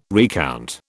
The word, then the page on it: recount: British English